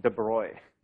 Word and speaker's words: de Broglie: So this is okay, so people say that that's pronounced de Broglie. Okay, I don't get it, but so we'll go with de Broglie.